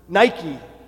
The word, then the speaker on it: Nike: And when he arrived there in the city of Athens, he said but one word, Nike, or as the famous athletic company today pronounces it, Nike.